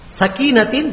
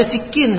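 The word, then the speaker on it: Sakinatin (سكينة): Sakinatin dibaca sikin sama dia.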